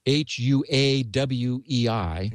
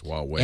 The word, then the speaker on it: Huawei: H U A W E I. It's Huawei. – And, I think they pronounce it Huawei.